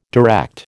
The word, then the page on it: direct: American English